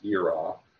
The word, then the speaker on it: yirah: Y-I-R-A-H. Pronounced Yerah.